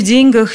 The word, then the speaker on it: деньгах: Ведь не в деньгах же счастье.